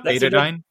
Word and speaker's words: Betadine: Yeah, Betadine, I believe, is how you say this thing, and this is the new quack cure. So if you're on Wall Street bets, Betadine, iodine, that's their new thing. – Yep, Betadine.